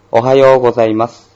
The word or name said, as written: ohayou gozaimasu